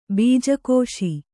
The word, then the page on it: ಬೀಜಕೋಶ: bīja kōśa